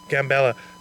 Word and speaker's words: Gambela: Now, who is in this region, Gambela? Is that how you pronounce it? – Gambela.